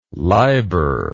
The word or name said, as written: liber